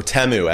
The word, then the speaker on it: Temu: Number four um, uh, was Timu or Temu, as we all mm-hmm.